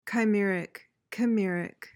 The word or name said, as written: chimeric